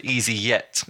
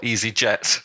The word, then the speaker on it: easyJet: he was really disappointed that Johan pronounced it easy yet and not easy jet.